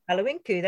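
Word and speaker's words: Galiwin'ku: Galawinku. Have I said that right? – Galawinku, that's right, yeah.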